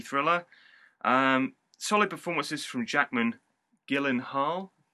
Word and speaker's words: Gyllenhaal: Be careful with that pronunciation. You will Gyllenhaal. – Gyllenhaal. You will get your head snapped off in certain circles if you mispronounce that.